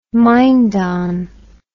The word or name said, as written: mindon